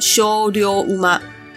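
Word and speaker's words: 精霊馬: 日文念作修溜乌妈。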